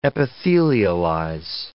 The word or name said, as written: epithelialize